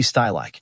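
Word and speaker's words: Stielike: Nur mit der Aussprache seines Namens hapert es hier und da. – Ulrich Stielicke. better known as Uli Stajlak.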